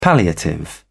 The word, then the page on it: palliative: British English